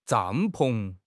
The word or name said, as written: 帐篷